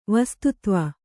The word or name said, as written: ವಸ್ತುತ್ವ